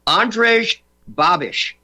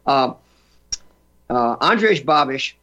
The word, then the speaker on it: Andrej Babiš: Andrej, A-N-D-R-E-J. Remember his name. Babiš. B-A-B-I-S with a upside-down circumflex on top of the S. Reverse of the French circumflex. Andrej Babiš, known as the Czech Trump.